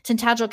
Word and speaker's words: Tintagel: He runs to Tintagel, I think is how it's pronounced. I think I was pronouncing it wrong last time. Tintagel Castle.